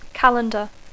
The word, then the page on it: calendar: English